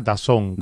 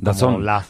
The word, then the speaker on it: DAZN: Creo que se pronuncia DAZN, DAZN. Como la zona en así.